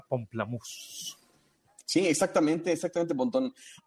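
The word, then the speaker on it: Pomplamoose: Aunque estoy viendo que se escribe diferente, se escribe Pompla Muse, como si fuera mousse de alce, pero suena justamente a pamplemousse, que pamplemousse en francés significa toronja.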